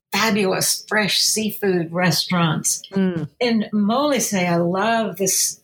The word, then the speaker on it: Molise: In fact, I didn't remember ever hearing about this place, Molise. Am I saying that right? – Yes. The